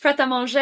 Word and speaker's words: Prêt-à-Manger: It's called Prêt-à-Manger or something. – It's French. Prêt-à-Manger. It's like ready to eat.